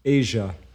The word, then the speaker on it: Aja: It's pronounced Asia, but it's spelled Asia.